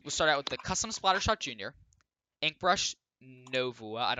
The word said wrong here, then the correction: Nueva: don't know how to pronounce that brand, Slosher Nueva.